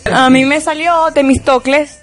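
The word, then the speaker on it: Temístocles: No, eso no es difícil, mira, Temistocles.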